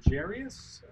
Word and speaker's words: Jairus: Jairus.